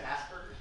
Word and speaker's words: Asperger's: I guess Osberg's. – I don't think that's how you pronounce that. – Is it Asperger's? – Yeah.